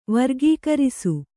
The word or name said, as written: ವರ್ಗೀಕರಿಸು